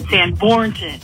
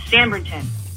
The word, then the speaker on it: Sanbornton: Talking about pronunciation, Kelly always says Sanbrington wrong. He says Sanbornton. It's Sanbrington. Sanbrington, Sanbrington.